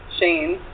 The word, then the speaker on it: Shaene: It's just at Shaene. My name is spelled Bunny, S-H-A-E-N-E.